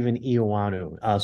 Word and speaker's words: Eoannou: My name is Steve Eoannou.